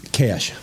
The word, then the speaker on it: Kasch: How do you pronounce the last name? – Cash. Like Cash. Johnny Cash. – Really? Like, yep. Johnny Cash? – Yep. – Only spelled K-A-S-C-H.